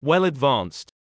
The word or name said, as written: well advanced